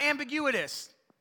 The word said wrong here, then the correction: ambiguous: Am- ambiguous. I knew I threw in an extra syllable in there somewhere. I was like, That didn't sound right.